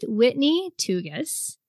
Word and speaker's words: Whitney Tougas: Today, I am having an amazing conversation already with Whitney Tugas.